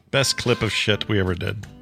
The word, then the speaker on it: Pschitt: That shit, for those of you listening, is spelled PSCHITT exclamation point. – Yep, silent P, baby. Shit. Like shit. Like psychology or anything else, you know.